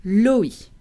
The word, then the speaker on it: loi: When you see the word L-O-I in French, L-O-I, which is the French word for low, well, you would want to pronounce it loi, right? Well, you'd be terribly wrong because the combination O plus I is making the wa sound. So it's not loi, it is loi, loi.